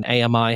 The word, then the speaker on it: AMI: Anyone who's heard my whole shtick on AMI has three syllables knows exactly what I'm talking about.